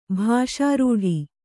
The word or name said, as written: ಭಾಷಾರೂಢಿ